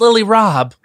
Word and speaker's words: Lily Rabe: And she, maybe it's Lily Rabe is the killer. – I think, well, you know, that's pronounced Lily Rob. – Lily Rob is the killer. Broccoli. – Like Broccoli Rob.